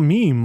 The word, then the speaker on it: meme: It's a meme. I totally forgot that. I didn't know that's how you pronounce it. – Did you think it was a meme? – Yeah. It looks like meme.